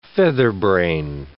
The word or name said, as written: featherbrain